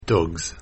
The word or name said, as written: Dogs